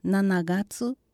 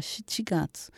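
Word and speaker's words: shichigatsu (七月): E para pronunciar o mês de julho, em japonês, tem duas formas, nanagatsu e shichigatsu. Qual é a maneira correta? Você já se perguntou por que existem diferentes formas de pronunciar? Originalmente, a pronúncia mais comum era shichigatsu, e essa é a leitura correta. No entanto, como pode ser confundido com janeiro, que é ichigatsu, tornou-se mais comum pronunciá-lo como Nanagatsu.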